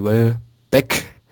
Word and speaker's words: Welbeck: Aber auch Danny Welbeck wird treffen. Ja, Betonung auf Beck, weil Rooney bestimmt auch, wenn er wieder fit ist.